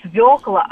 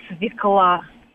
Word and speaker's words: свекла: И еще просто бесит, когда говорят не «свекла», а «свекла». – Угу. Ну, это уже, это правило, да, ударение.